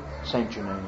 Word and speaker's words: Genet: or is that how you say it? Jean Genet? – Saint Genet, yeah. Saint Genet. It would be Saint Genet.